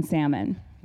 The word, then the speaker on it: Salmon: I do I pronounce the L? Salmon. I'm Alaskan. We don't pronounce the L in salmon. So I want to pronounce the salmon, but we'll just say Salmon.